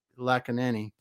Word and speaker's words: Lakkineni: But Krishna Lakkineni, oh, excuse me, say your last name for me because I'm getting Lakanani. – Lakkineni. Yes, I said it right the first time earlier, but I couldn't get it again from ROI Media Works.